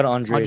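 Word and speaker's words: Andrade: The reason I said we don't really have to talk about the women's flyweight, the first one, Andre, is that how you say her name? Andre? – Yeah, Jessica Andre.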